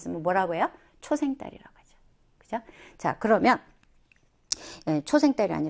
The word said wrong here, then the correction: 초승달: 아니라 초승달. 발음을 잘못했어요? 초승달.